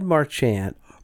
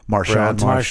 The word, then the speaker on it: Marchand: But Brad Marchand. – Marchand, sorry.